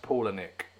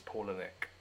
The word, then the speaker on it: Palahniuk: His name is pronounced Palahniuk. So I pronounced it completely wrong. Palahniuk. So for the record, I've mispronounced his name for the entire podcast, and it is Palahniuk.